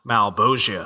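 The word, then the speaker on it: Malebolgia: We then see, we finally see this cackling, this laughing demon who is, of course, how do you say it? Malbolgia? – Malbolgia. – Malbolgia. All right.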